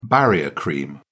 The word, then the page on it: barrier cream: British English